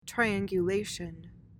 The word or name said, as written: triangulation